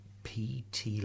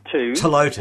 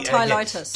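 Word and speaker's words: Ptilotus: Tilotus. – Actually, I was given one. – Yes, Tilotus. – Tilotus, yes. Australian plant. – Yeah, you don't have to pronounce the P.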